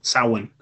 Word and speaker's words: Samhain: Samhain or Samhain or Samhain, something like that? – Samhain.